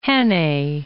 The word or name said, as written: Hannay